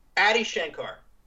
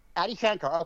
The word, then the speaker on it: Adi Shankar: Adi Shankar. – Adi Shankar.